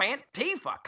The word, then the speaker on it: Antifa: I don't want to be on a beach beating up some anti-fa. They need a better name. Can we give them a nickname? We should have a contest. – It's actually pronounced Antifa.